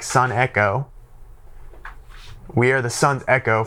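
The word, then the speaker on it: sun echo (συνέχω): What's important to note here is that in the Greek, the word is sun echo. That's how you pronounce it, sun echo.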